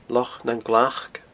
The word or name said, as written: Loch nan Glac